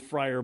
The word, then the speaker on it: Freyr: Koch Strategic Platforms, an investment unit of Wichita-based Koch, formed a 50-50 joint venture with Fryer Battery in October of 2021 with the intention of identifying a North American site for a gigafactory. Fryer, by the way, is spelled F-R-E-Y-R.